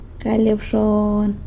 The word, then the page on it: galy abxon: sanlucasquiavini